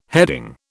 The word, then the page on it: heading: British English